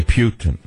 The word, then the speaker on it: Putin: I say Putin because it reminds you of Rasputin.